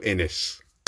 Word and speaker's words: Innes: And he has a surname that's pronounced like Guinness. Welcome to the stage, the brilliant Daniel Innes.